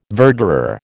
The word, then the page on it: verderer: American English